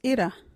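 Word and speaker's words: irra: Our local Wadjuti word is itta.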